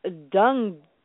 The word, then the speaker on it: dengue: It's the Zika virus versus dengue. That's how it's pronounced. It's spelled D-U-N-G-U-E, and I'm pretty sure it's dengue.